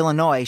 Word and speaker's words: Illinois: But I guess once you start pronouncing the S on the end of Illinois, shit's already off the rails, so who cares what I say?